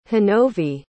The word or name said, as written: honovi